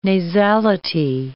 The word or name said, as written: nasality